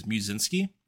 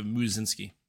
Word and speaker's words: Musinski: Uh, people didn't know how to re- pronounce my last name, which is Musinski, and it's uh, so they just call me Moose instead of Musinski.